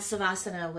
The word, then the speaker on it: Savasana: And that Savasana was like, am I saying it right?